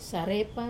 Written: sarepa

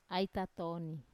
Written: ai tatoni

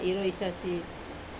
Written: resasi

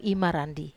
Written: marandi i

